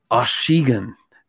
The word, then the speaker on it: Achigan: And the guy was like, Achigan.